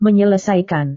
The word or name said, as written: menyelesaikan